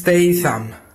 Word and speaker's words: Statham: Η σωστή προφορά του ονόματο είναι Jason Statham. Α, τι ωραία. – Jason Statham. – Έχετε και πάρα πολύ ωραία φωνή. Πάμε άλλη μία. Η σωστή προφορά του ονόματος είναι Jason Statham.